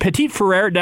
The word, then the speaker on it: Petit-Frere: Nicholas Pet, Pet, is it Petit-Frere? Am I, am I pronouncing that correctly? – There you go.